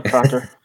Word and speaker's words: Prater: oh, so Olympia Looping is being set up at Warner Prater. Is that how you say it? – Vienna Prada. – Shut up. I hate you. Vienna Prater.